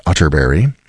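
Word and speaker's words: Atterberg: It's spelled Otterberg, but pronounced Otterberry. I have it on good authority.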